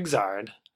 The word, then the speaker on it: Xrd: that's how you pronounce that. XRD. But if you look at the katakana, it's Iguzado. E starts with, so Xrd.